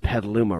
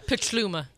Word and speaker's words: Petaluma: "It's pronounced Petaluma, right?" – Petaluma, actually.